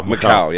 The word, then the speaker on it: Macau: Macau, yeah.